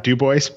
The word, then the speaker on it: Dubois: It's actually Scott Dubois, but that's quite all right, Andy.